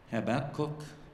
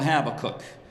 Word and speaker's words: Habakkuk: Now, maybe some of you call him Habakkuk. It depends on what syllable you put the emphasis on. I call him Habakkuk because that's how I was raised.